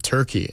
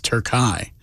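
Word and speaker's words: Türkiye: But, also, I was going to say, the next game after Australia for Team USA is not Turkey. It's Turkey. – Okay. – I was pronouncing it wrong.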